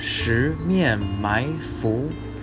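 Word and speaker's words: Shi Mian Mai Fu (十面埋伏): Shi Mian Mai Fu.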